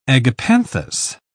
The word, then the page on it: agapanthus: British English